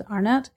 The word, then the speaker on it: Arnott: Yeah, S-H-A-U-N-A. And then my last name's Arnott, A-R-N-O-T-T.